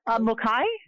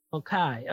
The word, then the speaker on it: Mulcahy: Mulcahy. – Mulcahy.